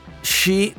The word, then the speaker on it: Xi: Lui pronuncia Xi, ah, Xi come il tuo Brexit.